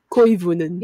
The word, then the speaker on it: Koivunen: Yeah, loosen your tongue up. – Koivunen. – Yep, there you go. – Okay, like a koi fish.